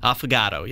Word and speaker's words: Affogato: Affogato, yeah. – Affogato. – Affogato, yeah. You got to accentuate it like that.